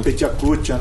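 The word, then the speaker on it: Pecha Kucha: O que, que foi aquele, não sei se eu vou pronunciar corretamente o nome aqui, é Petiacutia, é isso? – Petiacutia, né? É.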